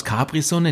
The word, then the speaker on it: Capri-Sun: Viele denken ja, die haben aus Capri-Sun einen englischen Namen gemacht. Alles ist bloß noch Englisch heutzutage. Capri-Sun. Dabei ist es kurpelzig. Haben die meisten noch gar nicht verstanden. Capri-Sun.